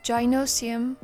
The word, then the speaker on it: gynoecium: So if gynoecium is the answer, the question is, what is the female reproductive organ of a plant? – So the male parts are called androecium. The word gynoecium, or some of you might pronounce it as gynoecium, comes from ancient Greek gyne, spelled G-Y-N-E, which translates to woman.